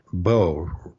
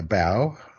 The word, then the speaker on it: bow: So what I said in the book is, if it shoots arrows, plays your violin, or secures your shoelaces, bow rhymes with go. Tie a bow in your shoes, play the violin with a bow, pull back the bowstring to shoot the arrow. But if it's a respectful bending of the body, a bow, or the front end of a ship, it rhymes with cow, and it sounds just like the bow on a tree.